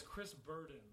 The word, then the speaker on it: Chris Burdens: No, it's Chris Burdens. – Chris Burdens? – Yeah, like with an S.